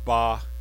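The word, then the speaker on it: ba (八): And I don't know in Romania, but here in Taiwan, it's 8 is pronounced ba, and August 8th is ba-ba, which sounds like father.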